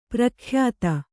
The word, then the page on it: ಪರಕೀಯತೆ: parakīyate